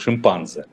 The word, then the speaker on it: шимпанзе: Вы согласитесь, вот в шимпанзе э, моя школьная учительница биологии делала, ставила ударение на второй слог – шимпанзе.